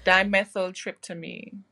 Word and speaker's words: dimethyltryptamine: Yes, dimethyltryptamine, if I said it correctly.